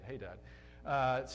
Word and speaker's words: Hadad: Apparently, that's actually how you say it, Hadad, starting in verse 14.